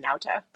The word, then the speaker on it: Nauta: Nauta? Is that what we decided? Okay. – I believe the first name is Waltine. I'm not sure, but the last name is pronounced Nauta.